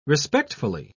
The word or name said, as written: respectfully